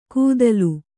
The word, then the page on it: ಕೂದಲು: kūdalu